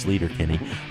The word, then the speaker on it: Sleater-Kinney: This apparently is the correct pronunciation for the off-ramp in Olympia on the highway that originally gave the group its name. We've been saying it wrong for 20 years, Sleater Kinney.